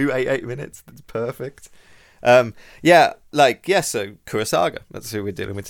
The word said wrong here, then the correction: Kurosawa: Akira Kurosawa.